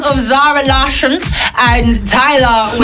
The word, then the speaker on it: Larsson: And he was like, Larsson. And I was like, okay. – He didn't say it like that, but he was like, it's Larsson. And I was like, okay, Zara Larsson.